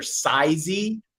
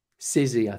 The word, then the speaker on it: Sizzy: It's either Sizey. That's how I say it. S-I-Z-Z-Y. Was that? – Sizzy, I think it's. – Sizzy? – Well, yeah, it's Sizzy. Spelled like Sizzy, but it's to show the sizes of your of yeah.